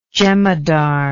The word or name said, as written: jemadar